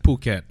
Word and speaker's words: Phuket: How do you pronounce it? – Phuket. – Phuket. Fuck it.